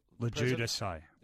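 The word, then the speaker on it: LoGiudice: How do you pronounce the name? Mark Lowe... – Lajudice. – Is that how to pronounce it? – Yep. – So it's L-O-G-U-I... – It's L-O-G-I-U-D-I-C-E.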